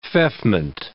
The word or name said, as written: feoffment